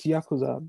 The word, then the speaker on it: Yakuza: It's Yakuza.